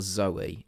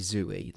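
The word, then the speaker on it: Zooey: Um, it's not Zoe. It's got to be Zoe, the way it's spelt.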